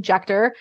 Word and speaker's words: Juechter: So J-E-N-N-I-E. And my last name, Juechter, J-U-E-C-H-T-E-R.